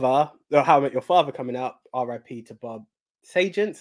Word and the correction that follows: Saget: I don't know how to pronounce his surname. – Saget, even. That would be the one.